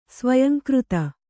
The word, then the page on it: ಸ್ವಯಂಕೃತ: Kannada